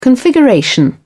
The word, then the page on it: configuration: British English